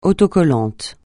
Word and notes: autocollant: francais

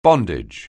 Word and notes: bondage: anglais

